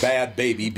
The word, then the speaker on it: Bhad Bhabie: Her name is Bad Baby, B-H-A-D-B-H-A-B-I-E. – Mm-hmm. Yeah, Bad Baby. Spelled like Bod.